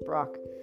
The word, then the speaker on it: Brach: I've been pronouncing her last name wrong. It's not Bach, it's Brock.